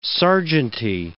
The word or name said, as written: sergeanty